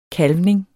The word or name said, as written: kalvning